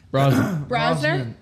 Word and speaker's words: Brosnan: I tell you, Pierce Bronson. – Brosnan? – Yep.